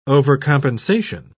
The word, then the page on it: overcompensation: British English